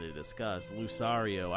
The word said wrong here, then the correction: Lucario: I don't even know if that's how you pronounce it, the Pokemon is coming to Smash Brothers Wii U. All right, eh? Huh? Anybody care? – Wasn't he was on Brawl, right? – Was he? – Yeah, Lucario was on Brawl. – That's how you pronounce his name, Lucario? – Yep.